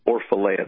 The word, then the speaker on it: Orfalea: There's a fantastic video done by a man named Matt Orfalea, that's spelled O-R-F-A-L-E-A. That's O-R-F-A-L-E-A, Orfalea.